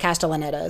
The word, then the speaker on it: Castellaneta: I think it's like Castellaneta is how you say that.